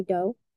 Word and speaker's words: Doe: My name is Tiffany Doe.